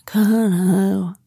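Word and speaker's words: Connaught: Carnahow. Det finns ett hotell med en jättekänd bar i London. Ja. Ni, ni som googlar typ på uttalet Carnahow Kano. Det är C-O-H mycket. Kano.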